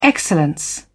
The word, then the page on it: excellence: British English